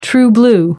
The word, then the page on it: true blue: American English